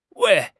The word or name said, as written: wei4